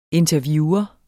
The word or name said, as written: interviewer